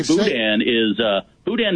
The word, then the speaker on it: boudin: Well, and by the way, boudin is a silent N.